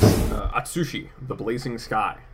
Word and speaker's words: Atsushi, the Blazing Sky: Atsushi, the Blazing Sky.